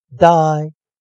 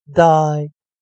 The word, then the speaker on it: dye: 好了，那同学们，我们今天带来生词，第一个生词 b y e bye bye bye 再见，d y e die die die 给点点点染色，所以同学们要记得 y e。